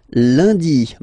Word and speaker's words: lundi: And in France, the week starts with lundi, Monday. Lundi, lundi. Okay, remember, UN is pronounced like un. Lundi, lundi, lundi.